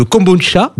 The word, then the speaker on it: kombucha: Squeezie aussi, le youtubeur numéro 1 qui a lancé une marque de kombucha. C'est comme ça qu'on prononce.